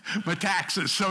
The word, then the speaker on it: Metaxas: I read Dietrich Bonhoeffer's Matanzas, I think. I don't know. I think that's the guy who wrote it. Metaxas. Is that it? Is that pronounced? Okay, Matanzas. Metaxas. Okay, thanks, Daryl, for that clearing up.